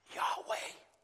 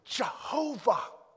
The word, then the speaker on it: Yahweh: We pronounce it Yahweh or Jehovah.